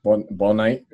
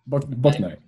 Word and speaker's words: Bouknight: Bouknight, that's how you say it. Bouknight? Bouknight, yeah. I like him, but I like Kai Jones as well.